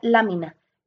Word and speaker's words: LMNA: La provoca una mutación en el gen LMNA, que se pronuncia lámina, encargado de producir una proteína estructural de las células.